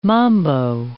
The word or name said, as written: mambo